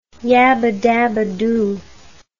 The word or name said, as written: yabbadabbadoo